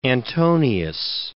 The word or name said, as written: Antonius